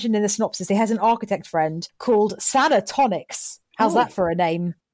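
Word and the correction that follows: Santonix: Santonics, sorry, I almost said it wrong.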